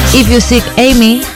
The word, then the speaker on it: If You Seek Amy: poniendo a alguna canción un título como If you seek Amy, si buscas a Amy, frase que en inglés se pronuncia exactamente igual que fuck me, follame.